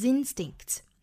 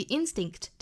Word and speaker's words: instinct: To 若读成 t, trust one's instinct, 这个 one 后面加上了 s 要读成 z, one's instinct 再和后面的 in 连读 one's instinct。那我们注意 instinct 这个单词呢要重读在第一个音节是 instinct, 不要读成了 instinct, 是 instinct, 那